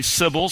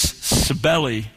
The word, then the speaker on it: Cybele: There's some discussion about the pronunciation. Some say Sybil, some say Cybele. but the sources I cited say it's Cybele.